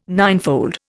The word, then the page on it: ninefold: Indian